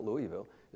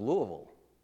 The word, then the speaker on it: Louisville: That's what I always called it, Louisville, Kentucky. No, it's not Louisville. It's Louisville. That's how you have to say it. You got to swallow the last syllable, Louisville, in order to say it right.